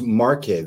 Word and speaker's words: Marche: It is pronounced Marche though, right? It's M-A-R-C-H-E. – Right.